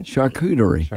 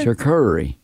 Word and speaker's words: charcuterie: Charcuterie. – Is there another R in there somewhere? – Charcuterie. It's a char and a cooter and an E.